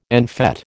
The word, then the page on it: en fête: American English